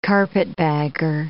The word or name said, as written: carpetbagger